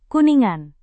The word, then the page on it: kuningan: indonesian